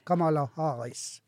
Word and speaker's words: Kamala Harris: Så jeg kan ikke skjønne den misnøye med, blant annet det som du så med hun Kamal Harris, hun kan jo snøre når jeg ikke uttaler navn, nei. Kamala Harris.